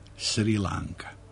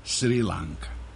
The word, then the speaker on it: Sri Lanka: Uzgred, prema izgovoru u jeziku Sinhala, odnosno sinhalskom jeziku, bilo bi Sri Lanka. Ostrvo je ostalo Ceylon, država je postala Sri Lanka.